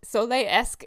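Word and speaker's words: Sol-Iletsk: It's very hard to say that Russian town, but Soleysk is what it said on YouTube when I typed in how to pronounce it. – Cirque du Soleil-esque.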